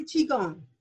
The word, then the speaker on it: Qigong: Actually, Qigong.